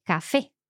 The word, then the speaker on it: café: So palabras agudas are the ones that are stressed on the last syllable. Mm-hmm. So like we said, café.